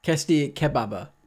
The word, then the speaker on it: tasty kebab: Testibaba? How do you say it? – Testy. – Testibaba. – Testy kebab. – Oh, kebab. Shit. – It's a kebab. – Oh, testy kebab.